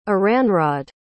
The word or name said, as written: Aranrod